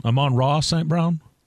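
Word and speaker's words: Amon-Ra St. Brown: Amon Ra St. Brown? – Yep. – Amon Ra St. Brown. – Amon Ra St. Brown.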